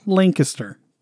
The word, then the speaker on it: Lancaster: Lancaster? Right. – Lancaster? – That's sure. How they say it there.